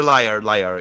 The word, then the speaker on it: lyre: The lure. I don't know how to pronounce it. I need your help, guys. You're gonna have, guys. – The lyre bird? – The lyre. Yeah, like, it's pronounced like lyre.